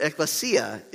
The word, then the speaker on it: Ekklesia: And I mispronounced that Greek word the whole series. That was embarrassing. I did. But Ekklesia is correct. That is correct.